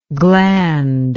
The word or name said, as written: glandlike